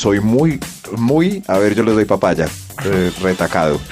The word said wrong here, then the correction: recatado: Lo dije bien. No doy papaya. Lo dije bien. – No, era recatado.